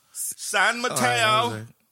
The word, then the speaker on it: San Mateo: Is it Mateo or Mario? – I don't know. San Mateo. – Mateo? You got to say it like that? – I don't know. – Mateo? – Yep.